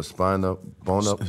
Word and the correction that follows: Spina Bifida: Spina Bifida. My youngest daughter. Yeah, it's a tongue tie. I mean, Spina Bifida.